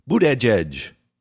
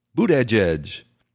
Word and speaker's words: Buttigieg: He started out this whole campaign telling people exactly how they were to pronounce his name, and it is Buttigieg, Buttigieg. And one of the memes, you know, on social media is to call him Buttigieg, and I think Nora O'Donnell just did that.